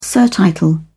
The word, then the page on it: surtitle: British English